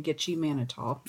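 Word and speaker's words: Gitchie Manitou: In November of 1973, five teens from Sioux Falls, South Dakota, went out camping in Gitche Manitou. And I don't know if I'm saying that right, but... – Sounds right to me.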